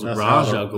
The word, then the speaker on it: Ra's al Ghul: That's how it's pronounced, believe it or not. – Really? Yeah, I thought it was Ra's al Ghul. – That's how the the original writer of the character. – Ra's al Ghul.